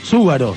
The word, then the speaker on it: Zúgaro: Quiero algo, el tercer programa y todavía no aclaré que mi apellido es Zúgaro. Z-U. Acento en la U. G-A-R-O, ¿no?